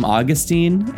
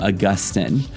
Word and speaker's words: Augustine: Now, please note, there are two different ways to pronounce the name of the aforementioned North African Christian leader. Sometimes I call him Augustine, and sometimes I slip into calling him Augustine.